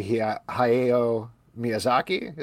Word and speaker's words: Hayao Miyazaki: So we had a nice sponsorship deal with this book called Shooter's Journey by Hayao Miyazaki.